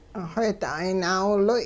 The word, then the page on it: wheeda'ay naawloy': hupa